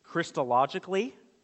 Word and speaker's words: Christologically: Fourthly, you should come to the Scriptures when you interpret them Christologically and evangelically.